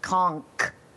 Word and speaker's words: conch: It's N-C-H. Conk. Yeah, there's an N in there.